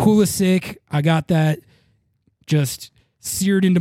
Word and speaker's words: Pulisic: I mispronounced it the entire episode. I called him Pulisic. I always do that. It's because when he was first coming up, that's how I read it in my head, and I would always say it like that. And then I realized, you know, later on, it's Pulisic, but it's just out of habit.